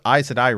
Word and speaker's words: Aes Sedai: You know what Aes Sedai rhymes with? Another failed organization from another fandom. Jedi.